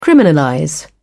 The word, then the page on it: criminalize: British English